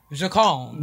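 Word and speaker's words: J'calm: Is it Jacalm? Jacalm.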